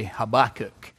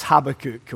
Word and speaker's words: Habakkuk: You will find him there just before the book of Haggai, just after the prophet Habakkuk, or as Americans say, Habakkuk. But it's Habakkuk, okay?